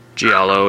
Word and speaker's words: giallo: It's got a cool feature on here of 50 years of Italian, what, how do you say it, Giallo? – Giallo, yeah.